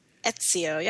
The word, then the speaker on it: Ezio: That's how you pronounce that. – Ezio, yeah.